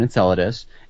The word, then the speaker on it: Enceladus: Is that Enceladus.